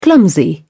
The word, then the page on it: clumsy: British English